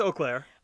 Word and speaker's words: Eau Claire: Eau Claire? How do you say that word? – Yeah, it's Eau Claire.